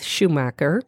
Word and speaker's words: Shumacher: For Sarah Faith Shumacher, did I say it right? – Yeah.